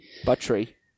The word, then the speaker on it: Buttrey: Buttrey. – Which I tried to confirm the pronunciation. I hope that's true. – It's true. I've heard it. – Buttrey.